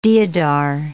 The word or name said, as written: deodar